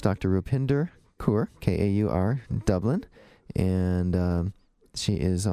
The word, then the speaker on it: Kaur: So I mentioned that we had Dr. Kaur, K-A-U-R, but pronounced Kaur, kind of like the beer.